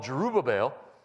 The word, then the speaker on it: Jerubbaal: Therefore, on that day, Gideon was called Jerubbaal Jerubbaal, I always try to put an extra syllable in there.